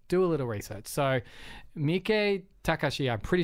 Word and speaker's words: Miike Takashi: So it's like Miike Takashi is how you pronounce it,